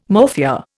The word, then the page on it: morphia: Indian